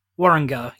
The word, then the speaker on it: Warringah: Warringah.